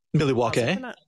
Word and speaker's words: Milwaukee: And think about, like, so we live part-time in Milwaukee, which originally is pronounced Milwaukee. – Milwaukee, meaning the good land.